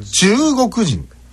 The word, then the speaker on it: 中国人: でこれが中国人をちゃんと言うと中の後の動く人だから中動く人で切るんですけど、2 箇所切るんですけど、まず中の後が上がるんです。上がるっていうかアクセントが強くなる、うん、中国人、うん、中国人、うん、中国人、中国人、うん、分かります？